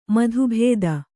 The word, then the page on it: ಮತಭೇದ: mata bhēda